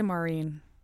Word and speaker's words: Maureen: I say Maureen, but a lot of people say Maureen. – How do you spell it? – M-A-U-R-E-E-N.